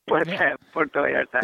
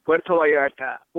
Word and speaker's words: Puerto Vallarta: So tell me how it eventually became a film about two white guys from America going to Puerto Vallarta. Yeah, I can never say it right. – Puerto Vallarta. – Thank you. – Yeah, well, Puerto Vallarta.